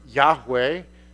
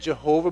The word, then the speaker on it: Yahweh: The word Yahweh is close to how you say it in Hebrew. It used to be said Jehovah,